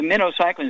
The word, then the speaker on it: minocycline: Okay, a day, and then also the minocycline is pronounced like the little bait fish.